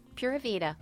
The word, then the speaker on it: Pura Vida: Pura Vida.